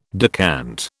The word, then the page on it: decant: British English